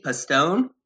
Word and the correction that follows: Poston: So close. – Poston? – That's good. Yes, it's Poston.